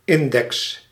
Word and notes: index: American English

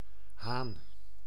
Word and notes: lås: [lɔːs]

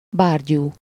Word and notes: imbecile: [ˈɪm.bɪˌsiːl]; American English

